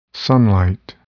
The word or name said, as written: sunlight